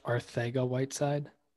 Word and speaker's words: Arcega-Whiteside: It's actually pronounced Arcega-Whiteside.